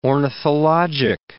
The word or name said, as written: ornithologic